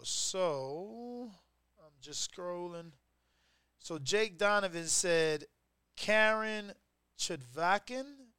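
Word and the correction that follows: Karen Chukhadzhian: I don't even. I, I'm sure I said that wrong. Let's get Jeffrey on it. Jeffrey. – Karen Chukhadzian.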